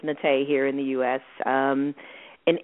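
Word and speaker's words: Naté: So what is the proper pronunciation? – It's Nate. – Nate. There you go.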